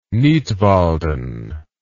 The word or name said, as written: Nidwalden